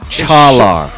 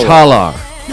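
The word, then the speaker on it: Çağlar: The issue is it's a soft G. And when I say soft, I mean so soft that it actually has no sound. So the first name, Chalar.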